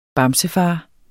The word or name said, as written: bamsefar